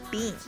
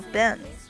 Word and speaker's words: been: 再有一个我要讲的就是这个 where you been, where you been 这个词呢，大家不必把它读成 been 啊，不用读成 been。你会发现大部分的美国人不会把它读成这个长音 e 的，而是会把它读成这个短音 a，甚至读成这个小口的 a， where you been。